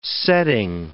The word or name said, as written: setting